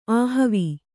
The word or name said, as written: ಆಹಾವ